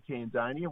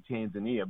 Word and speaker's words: Tanzania: Well, the locals say Tanzania. We say Tanzania.